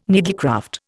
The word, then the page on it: needlecraft: Indian